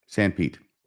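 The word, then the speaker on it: Sanpete: San Pete.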